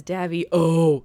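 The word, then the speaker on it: Daviau: I actually looked up how to pronounce his last name, and I'm pretty sure it says Davio. Like it's got the way they do it, they got like O-H at the end.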